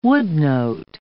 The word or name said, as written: woodnote